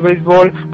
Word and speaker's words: béisbol: En México, el béisbol, pronunciado en México sin acento en la E, es el deporte más popular en las regiones norte y sureste.